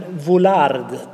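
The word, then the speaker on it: Vuillard: Men en konstnär som jag tittade på mycket där var en som heter, svåruttalat namn, men Vollard, tror jag man skulle säga.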